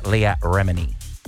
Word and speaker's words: Leah Remini: And there's two newcomers, Tracy Tudor, formerly from Million Dollar Listing, and then we've debated how to pronounce this a long time, actress Leah Remini. – Yes, I believe you've said that correctly.